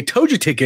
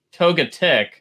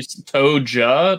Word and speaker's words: Togetic: Is that how you say Togetic? Because I'm pretty sure it's like Togepi, Togetic. I mean, maybe they switched it. How did I say it? – You Toja, like you're with a J.